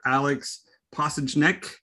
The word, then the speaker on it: Alex Pasiecznik: So I have the pleasure of getting to talk to Pastor Alex Pasiecznik.